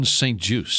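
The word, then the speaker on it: St. Juste: And one of their starting corners, how do you say it? Is it Juice? Just? St. Just? – Benjamin St. Juice.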